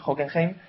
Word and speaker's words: Hockenheim: Y nada, nos vamos directos al Gran Premio de Alemania, al circuito de Hockenheim. Esta semana sí que lo digo bien, Iván, Hockenheim.